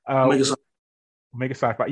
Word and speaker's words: Omega Psi Phi: But also doing my research, I see you're from member fraternity Omega Phi Psi, if I'm saying that correctly. Hope I am. Omega Psi Phi, yes.